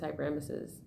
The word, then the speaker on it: hyperemesis: and all of a sudden now you're experiencing this hyperemesis. And that's how you pronounce it, by the way.